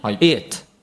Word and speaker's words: it: it it it もう一回 it it で、こう日本語的な発音、カタカナ読みをしてしまうと it っていうふうに、おの音が入っちゃうんですね。